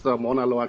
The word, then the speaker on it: Mauna Loa: And I can already assure you, you pronounced Mauna Loa correctly.